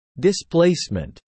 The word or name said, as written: Displacement